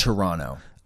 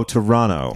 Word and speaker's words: Toronto: It's Toronto. – Oh, Toronto. – It's Toronto. – Toronto. No T. The second T is silent.